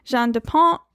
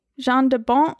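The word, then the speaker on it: Jan de Bont: It's directed by Jean Dupont. Didn't say that right. Jean Dupont. Is that right? – Yes.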